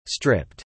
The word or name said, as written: stripped